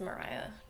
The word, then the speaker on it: Maria: The main character's name is actually spelled Maria, pronounced Mariah, just FYI.